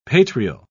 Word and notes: patrial: British English

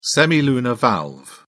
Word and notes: semilunar valve: British English

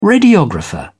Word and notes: radiographer: British English

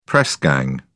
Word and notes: press gang: British English

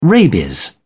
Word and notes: rabies: British English

